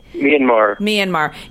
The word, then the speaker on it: Myanmar: But it seems like you've gone to a lot of different places, but specifically you like the Myanmar. Am I saying that correctly? Old Burma area. – Myanmar.